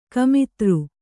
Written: ಗೋಮೂತ್ರ

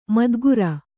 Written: ಮೈದಿಗುರ್

